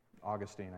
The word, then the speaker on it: Augustine: Augustine, I guess, is technically how you say it.